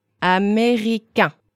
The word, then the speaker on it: américain: Américain. Américain. – And that's the masculine version, right? But it would be different for women? – Yes, absolutely. French language has genders. – Right. Masculine and feminine? – Yes, just two genders. And French adjectives agree to the gender. And nationalities are adjectives. – So, if if you were going to say you were American, how would you say it? – I would say, je suis américaine. – Repeat the male version one more time.